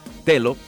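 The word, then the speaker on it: Teló: Ese Tello es con una L y acento en la O.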